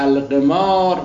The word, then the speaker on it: القمار: در مجمع البحرین جلد 3 صفحه 463 در همین ماده قمره داره که القمار بالکسر، تلفظش ظاهراً بالکسر صحیح هست، القمار بالکسر اللعب بالآلات المعدت لهو الا اختلاف انواعها و اصل القمار الرهن على اللعب بالشيء في هذه الاشياء.